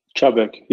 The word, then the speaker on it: Chubbuck: And if I pronounced your town Chubbuck, would I be correct? – Chubbuck, yep.